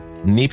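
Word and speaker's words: Kneipp: NIP Sueño Completo. O sea, eh, se escribe K-N-E-I-P-P, -P, p vale Sí, es, como inglés, ¿no? – No, se dice nip, se dice nip.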